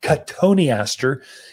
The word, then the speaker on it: cotoneaster: It's a great plant, cotoneaster. You'd spell it like cotton Easter. So everyone that doesn't know how to say the Latin, but cotoneaster is how you pronounce that one as you come in.